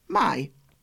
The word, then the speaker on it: maj: The month of May in Polish is Maj, pronounced Maj. It's M-A-J, Maj, and in English, M-Y.